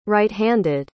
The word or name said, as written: righthanded